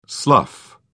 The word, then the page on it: slough: British English